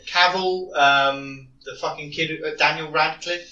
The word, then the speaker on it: Cavill: How'd you say his name? – The Witcher. – Cavill. – Is that how he says it? – It's Cavill. – Henry Cavill.